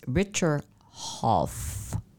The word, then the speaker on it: Richard Hough: And this is Richard Hoff.